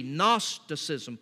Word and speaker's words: Gnosticism: First, there was Gnosticism, spelled with a silent G, Gnosticism.